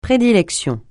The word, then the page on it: prédilection: francais